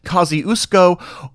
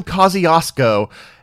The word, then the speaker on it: Kościuszko: In fact, I'm saying Kosciuszko today, but many of you may actually say Kosciuszko or even Kosciuszko, and there are even other variations.